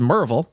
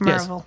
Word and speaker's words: Maryville: Uh, it, it, it's it's it's spelled Maryville, but it's you, you, you, the proper way to say it is Maryville.